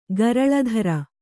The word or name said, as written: ಗರಲಧರ